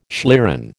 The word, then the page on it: schlieren: American English